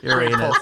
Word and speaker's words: Uranus: I'm going to ruin it and say Uranos. – Mm-hmm. – The correct pronunciation. – Well, yeah, in the future in Futurama, I believe they changed it to Eurectum, but I think it's still Uranus.